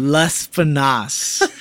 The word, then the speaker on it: Lespinasse: Lespinasse.